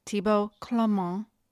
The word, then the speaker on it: Thibaud Clément: And Karina was schooling me on how to say his name, Thibaud Clément.